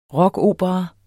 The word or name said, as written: rockopera